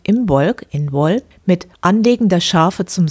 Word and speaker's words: Imbolc: Der keltische Name für Lichtmess wird Imbolc geschrieben und wird irisch ausgesprochen Inwol.